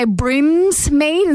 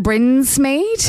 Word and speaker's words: Brinsmead: Some people like to say Brimsmead instead of Brinsmead.